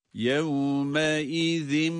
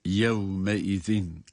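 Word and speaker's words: يومئذ: Bu yevme izinde her ikisinde de zal harfleri ince olarak ve peltek olarak okunacaktır. – يَوْمَ اِذِمْ بِجَهَنَّمَ يَوْمَ اِذْ. – Evet, her iki يَوْمَ اِذِنْ kelimesinin okunuşunda da ayrıca vav harflerinde dudakların öne uzatılmasını da unutmuyoruz.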